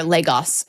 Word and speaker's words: Lagos: Or Lagos is typically how it's pronounced.